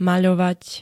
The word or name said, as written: maľovať